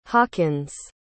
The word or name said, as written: hawkins